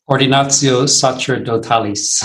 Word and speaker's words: Ordinatio sacerdotalis: Ordinatio sacerdotalis. – Ordinatio sacerdotalis.